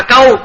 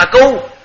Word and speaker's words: câu: Còn của mình ở đây á, chỉ câu, chỉ cu ly, chỉ cu, chỉ cu là đều, chỉ câu á miền Bắc đọc là câu, miền Nam là cu, thì là cái nghĩa là đều.